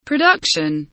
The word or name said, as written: production